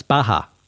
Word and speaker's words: Baja: Also, Lisa was kind enough to mention that I pronounced the state wrong, or the area in the particular state where they're launching the weather balloons with the geoengineering spray. It's Baja. And, you know, I have heard that before. And I don't know why I got it so badly wrong, but Baja.